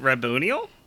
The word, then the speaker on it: Raboniel: Wait, how do you say her name? – You got it. – How do you say her name, Craig? Let us know. – I'm paranoid now. Is it Raboniel? – Yes. Got it. You're okay.